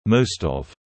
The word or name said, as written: most of